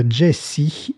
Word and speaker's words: Gyasi: compositeur et sensation glam rock Jesse, ça s'écrit G-Y-A-S-I, vient de sortir un nouveau single troquant son instrument préféré, la guitare, contre le piano.